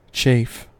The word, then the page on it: chafe: American English